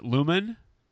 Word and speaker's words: Luman: Is it Luman? – Yeah, exactly.